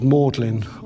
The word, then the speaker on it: Magdalene: I'd seen Magdalene pronounced as Maudlin, obviously, many times in Cambridge, and I thought there was something in there.